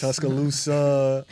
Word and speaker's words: Tuscaloosa: And then, of course, the trips to Alabama, to Mobile, and then, of course, to Tuscaloosa. Lusa, my bad. – You're on a roll with these pronunciations. – Tuscaloosa, Livingston.